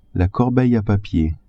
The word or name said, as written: corbeille a papier